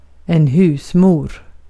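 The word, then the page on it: husmor: Swedish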